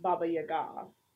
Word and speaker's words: Baba Yaga: She gets sent to the Baba Yaga, and but it's actually pronounced Baba Yaga, first of all.